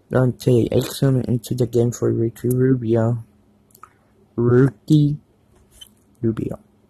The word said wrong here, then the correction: Ricky Rubio: Ricky Rubio, sorry.